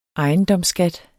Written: ejendomsskat

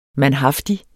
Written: mandhaftig

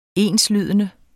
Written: enslydende

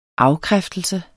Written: afkræftelse

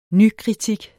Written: nykritik

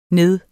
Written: ned

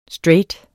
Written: straight

